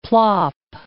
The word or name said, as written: plop